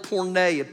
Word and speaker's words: porneia: Do you know one of the definitions for the word of sexual immorality is a word that's spelled porneia, P-O-R-N-E-I-A.